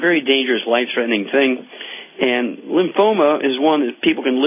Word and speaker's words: lymphoma: Lymphata, lymphoma. – No, thoma. Lymphoma. – Oh, lymphoma. – Yes.